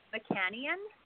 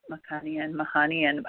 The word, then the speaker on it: Macanian: It's an Iranian name that's actually pronounced Mahanian, but Western people have a hard time with that. So you could just say it however you want. – Mahanian.